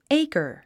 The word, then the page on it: acre: éikə r; エイカァ